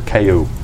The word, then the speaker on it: Kaiu: Kayu. – And what family are you? – That's the family. Is Kayu? K-A-I-U.